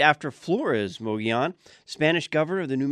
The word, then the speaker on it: Mogollon: The Mogollon Rim, they say, is probably one of the most mispronounced locations in Arizona. I say Mogollon. Locals pronounce it more Mogollon.